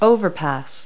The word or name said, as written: overpass